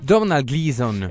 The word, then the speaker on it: Domhnall Gleeson: che vuoi pronunciare un attimo con l'accento gaelico? – Domhnall Gleeson, esatto.